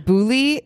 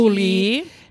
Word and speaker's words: Boulley: Angeline Boulley.